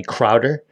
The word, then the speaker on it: Crowder: I have Johnny Crowder, or if you want to pronounce it correctly, it's Johnny Crowder, lead singer of the band Prison, suicide survivor, survived like almost a plane crash.